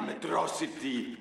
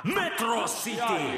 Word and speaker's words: Metro City: Täna on päev, mida sina ja Metro City niipea ei unusta. – Seda hääldatakse Metro City!